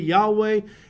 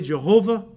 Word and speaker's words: Yahweh: So today we really don't know what the exact pronunciation of YHWH is. Probably Yahweh is the correct pronunciation, although most of us usually pronounce it in an Anglo-Saxon way, Jehovah. That's fine. There's no J sound in Hebrew, but okay, Jehovah, because that's the word we often use.